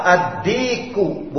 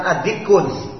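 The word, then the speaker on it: adiku (الديك): Begitu juga rojulun, rojulun apabila ditambah alif lam al, ar-rojulu atau dikun, Apabila ditambah alif lam, adiku, bukan adikun. Bukan adikun, tapi adiku.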